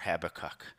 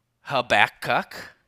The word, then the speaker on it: Habakkuk: I wanted to have a namesake for Habakkuk.